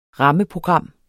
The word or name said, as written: rammeprogram